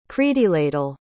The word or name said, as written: creiddyladl